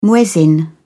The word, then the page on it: muezzin: British English